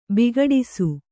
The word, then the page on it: ಬಿಕ್ಕಡಿಸು: bikkaḍisu